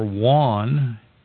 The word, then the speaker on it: yuan: I always say J U A N. If you are Hispanic, you'll know how to pronounce it, but it's Y U A N.